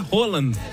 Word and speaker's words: Haaland: É, a pronúncia é Roland. Roland. Mas a gente fala Haaland, né? Fica meio esquisito o Roland.